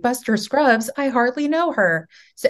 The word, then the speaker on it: Scruggs: Am I saying it correctly? Shrugs? – Scruggs. – Scruggs. Damn it.